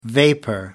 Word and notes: vapour: American English

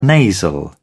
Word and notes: nasal: British English

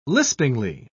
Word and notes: lispingly: British English

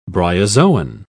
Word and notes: bryozoan: British English

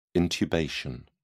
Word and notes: intubation: British English

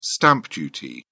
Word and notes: stamp duty: British English

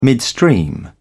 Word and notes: midstream: American English

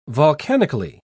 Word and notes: volcanically: British English

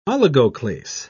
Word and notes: oligoclase: British English

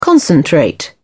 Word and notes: concentrate: British English

